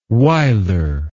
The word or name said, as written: Wyler